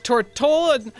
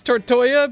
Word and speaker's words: Tortola: Okay, that was the Blue Hawaiians with Tortola. I'm going to say Tortola. It's spelled like it's Tortoya, but I looked up. I tried to find out what that actually is, and all I could find were World of Warcraft references.